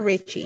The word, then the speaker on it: Ricci: Yeah, and I didn't realize Christina Ricci or Rika, I can't pronounce her name, but Christina Ricci, yeah.